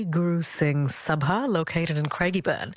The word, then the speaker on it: Craigieburn: Carol from Craigieburn, am I saying it right, in Melbourne? – Yes, you are.